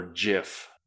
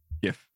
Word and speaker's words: GIF: Well, I, the one that gets me going, and Rob didn't get a chance to ask it, is GIF or GIF? GIF. So, GIF, you said with a hard G?